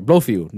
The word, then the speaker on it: Blofeld: You want to say, oh my God, this guy is bigger than Blofeld, uh, Blofin. I'm sorry, Blofeld. Blofeld. Yeah, Christopher Waltz.